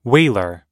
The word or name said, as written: wailer